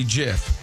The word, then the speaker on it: GIF: He said the G is soft, so you would pronounce it like the peanut butter brand. – Okay. – Jiffy, only GIF.